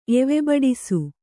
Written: ಎವೆಬಡಿಸು

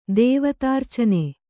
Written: ದೇವತಾರ್ಚನೆ

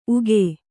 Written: ಉಘೇ

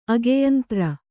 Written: ಅಗೆಯಂತ್ರ

